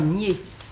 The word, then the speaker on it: Nied: Et puis, il y a aussi deux rivières de Lorraine qui doivent se prononcer nier. – Non, je ne crois pas. – Qui s'écrivent N-I-E-D, mais qui ne sont en pays de langue française, et les gens du pays disent nier. Mais, alors, ils vous disent même, vous prononcez ça comme pied.